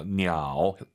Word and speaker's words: 鸟: 삼성이 마지막 글자면 올리지 마세요. 냐오. 하지만 사실은 보통 량지냐오. 더 중국스럽게 발음하시려면 반삼성으로 딱 끝냅시다.